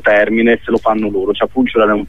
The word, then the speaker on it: Chapulcular: Sì, diciamo, la pronuncia è più o meno quella, Chapulcular, è una specie di sostantivo che poi ha varie declinazioni, Chapulcu, Chapulcular e quant'altro.